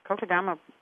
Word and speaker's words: Kokedama: is Kokedama? – Yes, Kokedama.